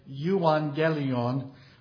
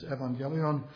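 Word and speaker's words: evangelion (εὐαγγέλιον): If you've been around too many preachers who went to seminary, you'll hear them say euangelion, but as Zach and his mother will attest, the proper pronunciation is evangelion.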